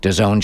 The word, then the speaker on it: DAZN: DAZN is D-A-Z-N, pronounced DAZN, app or website.